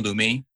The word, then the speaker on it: Lumi: At Gloomy Loomy. Gloomy, just like it's spelled, L-U-M-I.